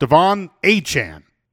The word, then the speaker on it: Achane: That's how he says he wants it pronounced. Devon Achane.